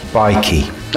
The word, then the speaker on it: Baiki: This band, Bakey, I'm calling them Bakey, it might be Bikey, probably Bikey, B-A-I-K-I. How would you say it? – Bikey. – All right, fair enough. Let's call them Bikey, because I've always trusted you with pronunciation.